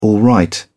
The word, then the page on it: alright: British English